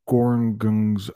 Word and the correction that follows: Gorongosa: I practiced pronouncing that national park. Gorongosa. Gorongosa is how we say it.